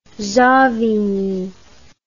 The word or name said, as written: savigny